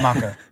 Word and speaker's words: Mako: Blame it on apparently everyone understandably always pronounced that as Mako in their heads. – Me too. – Now Mako, everyone's finding out that it's pronounced Mako.